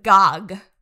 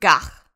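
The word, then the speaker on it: gagh: Yeah, he's eating Pippius Claw, Heart of Targ, and he says Gog. But it's pronounced Gach, I believe.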